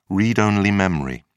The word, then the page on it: read-only memory: British English